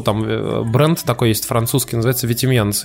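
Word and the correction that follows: Vetements: Я говорю, Витименс, он такой, он такой посмеялся, посмеялся, говорит, не, он там не Витименс, а Ветмон.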